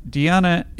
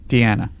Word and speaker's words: Deanna: And Andrew also adds, also, Deanna is... Am I saying it right now? – Nope. – Deanna.